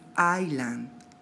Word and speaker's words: island: Por ejemplo, siempre escucho que dicen Island. No es Isla, es Island, Island. La S, en este caso, no se pronuncia.